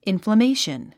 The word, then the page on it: inflammation: ìnfləméiʃən; インフラメイション